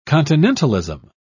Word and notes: continentalism: British English

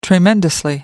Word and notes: tremendously: British English

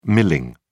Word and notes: milling: British English

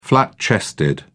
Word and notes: flat chested: British English

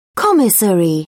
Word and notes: commissary: British English

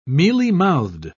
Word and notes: mealymouthed: British English

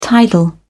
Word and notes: tidal: British English

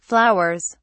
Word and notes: flowers: British English

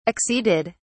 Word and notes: acceded: British English

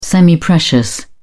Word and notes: semiprecious: British English

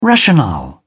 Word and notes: rationale: British English

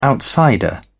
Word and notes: outsider: British English

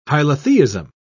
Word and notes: hylotheism: British English

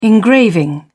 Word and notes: engraving: British English

